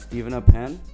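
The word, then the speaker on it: Steven Appen: My name is Stephen Appen, and it's spelled S-T-E-V-E-N-A. Last name is Pen, P-E-N.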